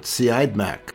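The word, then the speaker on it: Siodmak: It was directed by Robert Siadmak.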